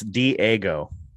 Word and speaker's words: Diego: Pronounce it this way. It's Diego. – It's D-A-G-O is how he spells it. – It's Diego. I know. Pronounce it that way. Diego.